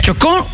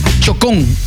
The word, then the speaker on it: Chocó: Oye, Shakira se compromete con la reconstrucción educativa del Choco. Bueno, se dicen así, ¿no? Chocó. Así lo dicen, ¿no? Bueno, Shakira lo dicen Chocón. ¿Qué tal? ¿Cómo se pronuncia esto, ah? Aquí está la tilde, ¿no? Chocó, ¿no?